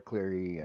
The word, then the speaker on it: Cleary: Oh God, the judges, Derek Cleary, or however you say his name. Man, Cleary, you got it right.